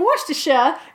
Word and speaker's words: Worcestershire: If you look in my notes, that's me phonetically spelling Worcestershire in the British accent, because I know that in New England it's Worcester. That is not how they say it in the UK.